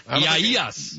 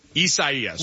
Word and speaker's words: Isaias: College game day going down there could be a wet and wild one, as we got a tropical storm, Isaisa. Is that what, where are we at with the pronunciation on this? – Isaias. – No one knows. – Isaias.